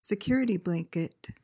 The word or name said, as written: security blanket